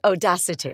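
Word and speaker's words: Odacité: do you know Odacite? I'm not saying it right. – No, it's Audacity.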